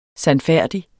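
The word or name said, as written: sandfærdigt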